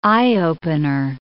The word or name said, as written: eye-opener